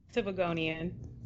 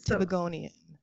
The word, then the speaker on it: Tobagonian: She is the child of a Trinidadian father and a Tobagian. Am I saying that correctly? – Tobagonian. – Ah! This is the place where we learn. Tobagonian.